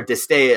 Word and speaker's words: De Stijl: The White Stripes De Stijl or disdain uh, if you're going with the Dutch pronunciation of it.